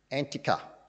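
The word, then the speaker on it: Antakya: Antioch. Do you know how it's pronounced today? Antica.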